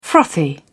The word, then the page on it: frothy: British English